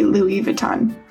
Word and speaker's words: Louis Vuitton: Louis Vuitton 就是这个 LV 它的这个正确的发音。 – Yeah, yeah. Maybe if someone can speak French, they s- can say it more beautifully. But in America, they will just say Louis Vuitton.